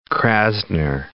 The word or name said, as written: Krasner